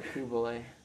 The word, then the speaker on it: Coulibaly: Coulibaly. – Coulibaly.